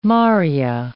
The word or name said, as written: Maria